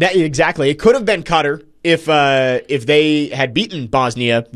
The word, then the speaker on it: Qatar: Way it's pronounced. Qatar. That is the correct pronunciation.